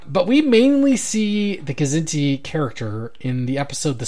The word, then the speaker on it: Kzinti: Did we decide it was Kzinti or Zinti? – I think it's pronounced both ways. – Zinti? – I've heard it both ways. – Okay. Zinti is dangerously close to Zindi, so maybe I'll pronounce the K.